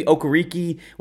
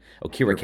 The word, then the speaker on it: Okereke: His pronunciation used to be Okereke when he was in college. And then, you know, he's one of those guys that like once he got to the NFL, he finally said, no, that's not how you pronounce my name. And Akira K.